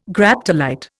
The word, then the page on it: Graptolite: Indian